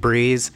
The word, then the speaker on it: BRZ: It's pronounced Breeze, spelled B-R-Z.